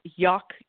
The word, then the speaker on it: Yough: and I know they're on Instagram as Yak Yarns, but it's spelled Y-O-U-G-H Yarns, so I thought that was pretty funny.